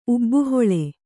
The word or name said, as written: ಊಬುಹುಲ್ಲು